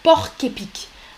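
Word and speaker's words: porc-épic: En français, on dit le porc épique et on, on lit les deux mots, porc épique porc épic, là on prononce le C. Normalement, on ne prononce pas le C. Hein. Vous savez, un porc, un cochon, P-O-R-C, mais on ne prononce pas le C. Avec cet animal, on prononce porc épic.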